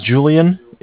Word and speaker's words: Julian: Okay, my name is Julian. It's J-U-L-I-A-N.